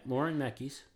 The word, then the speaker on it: Laurent Mekies: So, Ferrari's Laurent Mekies, I hope I'm pronouncing that right. – Yeah, Laurent Mekies.